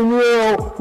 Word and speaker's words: Nero: Dante Nero rhymes with zero because you loser, man loser.